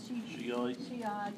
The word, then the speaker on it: Shiite: Talking his daddy being a Shiite, how do you say that? – Shiite or something like that.